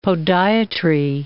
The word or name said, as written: podiatry